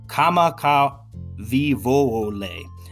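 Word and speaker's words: Kamaka Vivoole (Kamakawiwoʻole): And the late Hawaiian musician is Israel Kamaka Vivoole.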